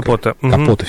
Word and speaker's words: Капоте: А в «Нью-Йоркере» вышла статья, я могу делать ошибку в ударении, но, насколько понимаю, автора звали Трумэн Капоте, наверное, французская. – Капоте все-таки, да. Вот, он написал «Хладнокровное убийство», когда мы стали отсчитывать этот жанр.